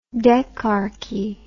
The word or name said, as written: decarchy